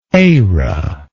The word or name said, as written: ara